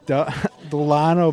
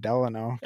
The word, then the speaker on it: Dalano: Um, but then they also brought in er, Dalano Banton. – there you go. – Um, it's tough name. I kept wanting to, I keep wanting to pronounce it Delano.